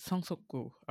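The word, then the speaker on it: Song Seok-gu (손석구): and Song So-kyu-ko. I need to look at his name in Korean because I swear to God I'd be saying it wrong. Song So-kyu-ko. Right, it's Song Seok-gu.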